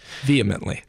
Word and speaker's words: vehemently: Vehemently, because I learned it in Latin first, vehementor, and so I can never say it correctly in English.